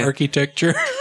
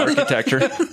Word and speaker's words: architecture: Well, she was doing originally, she went to school in Savannah, Georgia, and she was doing studying old architecture and that kind of stuff, and was doing a lot of art things, but I guess architecture? – Yeah, architecture. you gotta pronounce all them consonants.